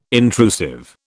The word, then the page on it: intrusive: British English